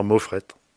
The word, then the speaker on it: Moffrette: Ben moi, c'est Bernard Moffrette. – Voilà, avec le T qu'on prononce, Moffrette. – Voilà, on s'arrête dessus, c'est comme ça en Bretagne.